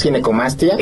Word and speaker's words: ginecomastia: Pues muchas gracias, y sí, definitivamente creo que es una, para empezar la palabra, le decía, ¿cómo se pronuncia? Ginecomastía. – Sí, exacto, es ginecomastia.